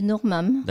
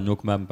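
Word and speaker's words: nước mắm: comment on le prononce bien Allez-y. Le normam. – D'accord. Donc nous on dira nokmam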